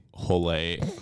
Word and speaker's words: Holé: And we say Holé, and then as soon as she walks off, we start uncontrollably laughing.